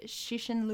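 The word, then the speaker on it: Cixin: Three-Body Problem by Xixin Liu. Is that its pronunciation? – Yeah, that's very close.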